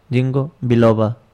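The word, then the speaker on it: Ginkgo biloba: Jingo, se escribe Jing con K. – Ajá. – Jingo Biloba. Así como suena.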